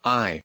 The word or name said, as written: eye